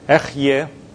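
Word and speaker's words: Ehyeh: God says my name as Echyeh.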